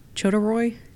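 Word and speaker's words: Chodorow: How do you pronounce this guy's name? Jeffrey Chodoroy? – That sounds right. – It sounded right.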